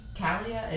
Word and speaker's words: Calea: Kalia is C-A-L-E-A. Kalia. – E-A. – Yep. And then the next word is Z-A-C-A-T-E. C-H-I-C-H-I-G-G.